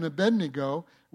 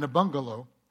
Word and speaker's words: Abednego: When Shadrach, Meshach, and Abednego were cast in the fiery furnace, I heard a pastor mispronounce those names. He said, Meshach, Meshach, and Abungalo.